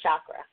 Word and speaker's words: chakra: Okay, and so now for those people who you mentioned chakra, mm-hmm. – and that, and I want to tell people how I first learned about chakra. Am I saying it correctly? Chakra, yeah. chakra.